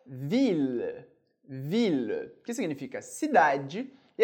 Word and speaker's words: ville: Muito importante que o E no final da palavra, ele não é pronunciado, ele desaparece. A letra que vem antes, no caso o L, a gente pronuncia, por isso ville, mas eu não pronuncio o E.